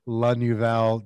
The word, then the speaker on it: Lenouvel: Lenouvel.